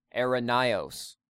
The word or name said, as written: Eirenaios